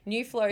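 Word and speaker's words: Nu Flow: New Flow is spelled N-U space F-L-O-W. And Big Brothers is spelled big, obviously, but Brothers is B-R-O-V-A-Z.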